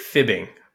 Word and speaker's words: F.I.B.ing: You can just pronounce it fibbing. – I know. Okay. I'm fibbing.